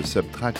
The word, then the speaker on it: SBTRKT: Et nous ouvrons cette dispute avec le nouvel album de Subtrack. Ça s'écrit SBTRKT, ça veut dire soustraire en anglais.